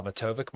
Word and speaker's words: Matovcik: So you can find me on Twitter at Matovic. My last name, because it's a little bit tricky, is spelled M-A-T-O-V-C-I-K.